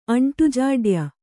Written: ಅಂಟುಜಾಡ್ಯ

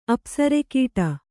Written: ಅಪ್ಸರೆಕೀಟ

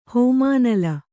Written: ಹೋಮಾನಲ

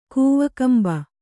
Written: ಕೂವಕಂಬ

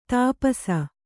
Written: ಧೂಪಿಸು

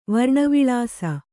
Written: ವರ್ಣವಿಳಾಸ

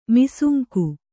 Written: ಮಾಸಂಕೆ

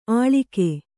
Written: ಅಳಿಗೆ

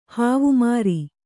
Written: ಹೇವಮಾರಿ

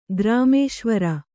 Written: ಗ್ರಾಮೇಶ್ವರ

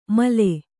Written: ಮಲ್ಲೆ

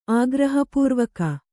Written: ಆಗ್ರಹಪೂರ್ವಕ

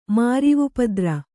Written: ಮಾರಿವುಪದ್ರ

